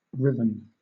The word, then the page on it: riven: British English